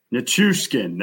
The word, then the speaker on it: Nichushkin: Nichushkin.